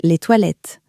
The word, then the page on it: toilettes: French